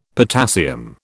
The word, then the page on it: potassium: British English